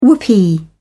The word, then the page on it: whoopee: British English